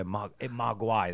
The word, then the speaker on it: mogwai: Yeah, mogwai, that's how you say it, right? – Mogwai.